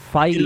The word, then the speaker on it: Feige: Pero de Disney sí que es la última, Marvel, y es que se ha confirmado por parte de Kevin Feige, o Figgy, o como cojones le pronuncié. – Feige.